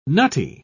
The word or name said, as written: nutty